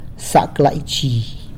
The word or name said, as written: Zac laizhi!